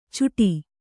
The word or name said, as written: ಚುಟಿ